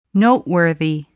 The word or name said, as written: noteworthy